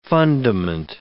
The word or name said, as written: fundament